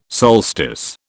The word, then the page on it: solstice: British English